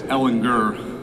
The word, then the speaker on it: Ellinger: I feel like the two older guys, Ellinger, with the hard G, the two older guys in Bouchel and Ellinger really improved in the areas that we asked them to.